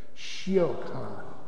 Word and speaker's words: Siochan: Siocan. It's spelled in English letters S-I-O-C-H-A-N, but that S-I-O sounds like she.